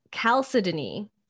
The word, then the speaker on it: chalcedony: So agate is a variety of quartz, specifically, now I put the pronunciation here, but I'm going to fuck it up. Chalcedony. – Oh, chalcedony? Good job.